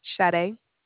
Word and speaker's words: Sade: Sade. – I'm sorry. Would you please spell that? – Yes. It's spelled S-A-D-E. – S-A-D-E.